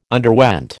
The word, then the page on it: underwent: American English